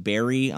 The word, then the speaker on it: Barrie: So you grew up in Barrie, Ontario.